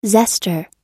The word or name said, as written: zester